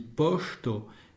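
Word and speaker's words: poŝto: Ça se dit posto et ça s'écrit P-O-S, chapeau, T-O.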